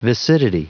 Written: viscidity

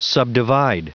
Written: subdivide

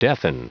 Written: deafen

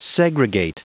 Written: segregate

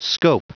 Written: scope

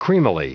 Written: creamily

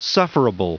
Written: sufferable

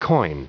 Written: coin

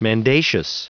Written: mendacious

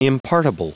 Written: impartible

